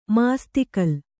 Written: ಮಾಸ್ತಿಕಲ್ಲು

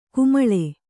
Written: ಕಮ್ಮಾಳ

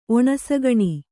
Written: ಒಣಸಗಣಿ

